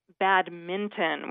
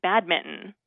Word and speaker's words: badminton: So it is pronounced badminton with an N. I had been saying badminton, like mittens that you wear on your hands.